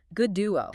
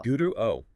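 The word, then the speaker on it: Godot: He insisted the emphasis is on the first syllable. So good duo. – Good duo, like God.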